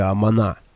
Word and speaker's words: Амана: Что-то вроде «Амана» с ударением на последнем слоге.